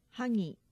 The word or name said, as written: hagi